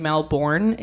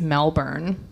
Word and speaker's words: Melbourne: So we just did some quick Googling, and I have been pronouncing, I was saying Melbourne, it's Melbourne.